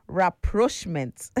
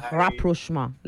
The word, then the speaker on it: rapprochement: I'm pronouncing the way the French pronounce it too. So if I'm pronouncing like the way English pronounce it, is rapprochement. But that's not how you pronounce it, but it's rapprochement is how it's spelt in English. But the pronunciation is rapprochement.